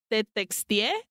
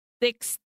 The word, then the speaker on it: Tetxie: I read it as Tetextie. – What is it? – It's spelled T-E-T-X-I-E con acento en la E. Textie.